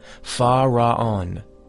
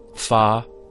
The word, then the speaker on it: Fa-Ra-On: It is sufficient to go to sleep, mentally pronouncing the powerful mantram, Fa-Ra-On. This mantram is divided into three syllables, Fa-Ra-On.